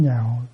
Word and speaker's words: nhạo: Và cái câu đầu tiên của Ngài nói là dĩ tín nhạo chánh pháp thì vi tối các tường. Chữ lạc này mình sẽ đọc là chữ nhạo.